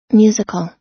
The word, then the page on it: musical: American English